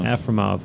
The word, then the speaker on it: Afremov: Afremov.